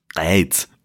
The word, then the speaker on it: Greiz: Ach so, man muss es anscheinend so aussprechen, so wie du. Greiz.